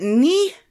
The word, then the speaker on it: Nih: We have, I'm actually going to, well, is that Nee? – I would just stick with their user handle. – Yeah, at Sweet Portwell, N-I-H is how it's spelled, and that would be the knights that say Nee in Monty Python.